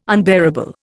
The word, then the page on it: unbearable: Indian